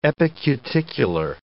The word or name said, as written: epicuticular